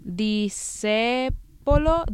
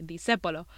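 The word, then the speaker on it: Discépolo: I don't know if I'm pronouncing his name correctly. De Sepolo. De Sepolo. The accent's on the E. De Sepolo.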